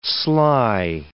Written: sly

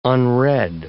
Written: unread

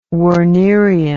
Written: wernerian

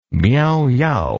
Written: Miao-Yao

